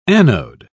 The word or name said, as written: anode